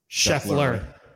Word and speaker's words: Scheffler: Is it Scheffler or Scheffler? Sorry. – Scheffler. – Right. Scheffler. Sorry. I mispronounced his name.